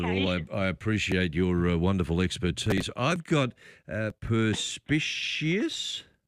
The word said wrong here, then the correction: perspicacious: No, perspicacious.